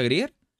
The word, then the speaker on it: Grier: Y Pam Grier, que es... ¿Se pronuncia Grier? ¿Grier? – Sí.